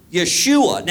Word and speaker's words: Yeshua: Yeah, that's how you say it. Yeshua.